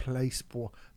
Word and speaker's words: Placebo: How do you say Placebo? Do you say Placebois? – No, I say Placebo. – Oh, you say it right.